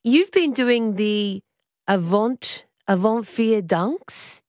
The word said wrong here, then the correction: Avond Vierdaagse: Is that how you pronounce the festival? – The Avond Vierdaagse.